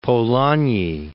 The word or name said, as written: Polanyi